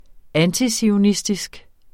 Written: antizionistisk

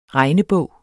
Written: regnebog

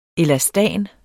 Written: elastan

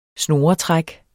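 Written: snoretræk

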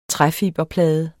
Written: træfiberplade

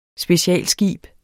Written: specialskib

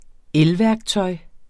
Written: elværktøj

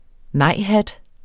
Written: nejhat

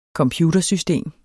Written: computersystem

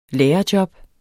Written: lærerjob

